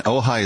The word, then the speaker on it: Ojai: And Ojai is spelled O-J-A-I. So, friends, if you're trying to figure it out, Calvary Ojai, O-J-A-I, is how you get there.